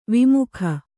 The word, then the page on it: ವಿಮುಖೆ: vimukhe; Kannada